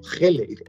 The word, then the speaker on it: Gele: Tu, email, Gele Rodrigo, y, dice, GL, no, Gele. Ah, GL, no, que es Gele.